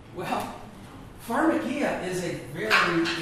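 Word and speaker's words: pharmakeia (φαρμακεία): And the Greek word pronounced here is pharmakeia. What does that sound like? – Pharmacy. – Exactly.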